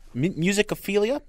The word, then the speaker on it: Musicophilia: I think the place to start as we dive into this new book, Musicophilia, is that the right way to say it? – Uh-huh.